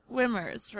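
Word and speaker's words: SWMRS: It's Swimmers, right? – Yeah. – But without the vowels.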